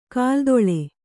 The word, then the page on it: ಕಾಲತಳ: kālataḷa